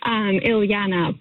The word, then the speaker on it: Ileana: Yeah, you said my name correctly, as my mother would describe it, which is only 3 syllables, Ileana.